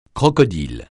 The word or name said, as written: crocodile